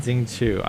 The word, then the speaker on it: Xingqiu: Xingqiu. I never know how to pronounce his name. – Yeah, the Q's are CH's.